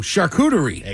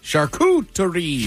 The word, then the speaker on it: charcuterie: You pronounce it like this. Charcuterie. – Charcuterie. – Charcuterie. Very good. – Oh, the mamma mia. – It's not cute. It's charcuterie.